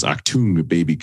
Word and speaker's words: Achtung Baby: That's how you pronounce Achtung Baby.